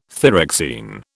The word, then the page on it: thyroxine: British English